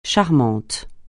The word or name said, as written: charmant